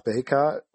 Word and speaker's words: Bacot: And then I have Garrison Brooks at power forward, and I have Armando Bacot. – Well, you had to say it first, but yeah, I think Bacot is the generally accepted pronunciation there.